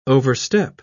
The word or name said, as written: overstep